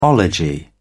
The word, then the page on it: ology: British English